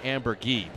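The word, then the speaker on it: Amburgey: If you missed it on the pregame show, the right here's new outfielder, Trey Ambergay, is here. Or Trey Ambergee, pardon me. Ambergee is how you pronounce it.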